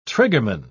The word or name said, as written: triggerman